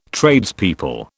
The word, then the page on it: Tradespeople: British English